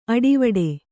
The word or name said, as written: ಅಡಿವಿಡಿ